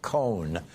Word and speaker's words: Cohen: Cohn, so that it sounded like he was Roy Cohn.